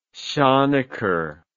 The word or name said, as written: shonicker